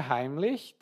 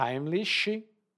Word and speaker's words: Heimlich: A pronúncia Heimlich também pode ser Heimlich na pronúncia brasileira, e é uma técnica para desengasgar uma pessoa que se engasgou.